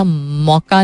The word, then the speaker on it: Mocha: अब साइक्लोन वैसे ही एक ऐसी चीज है और कैटेगरी 5 का साइक्लोन मौका इसका नाम इन्होंने रखा था. मौका नॉट विद कॉफी वाला मौका, मौका कैसा नाम है यार रखने वाला, MOCHA, माचो का ऑपोजिट मौका.